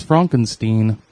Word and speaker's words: Frankenstein: Is it Stein or Stone? – It's Frankenstein. – Frankenstein. That would be Frankenstein.